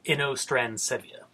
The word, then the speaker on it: Inostrancevia: In Transcephia or something like that. I'm sure I'm saying that wrong. – Probably. – Future me. – It's Inostrancevia. At least I'm pretty sure that's how you say it.